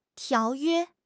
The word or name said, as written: 条约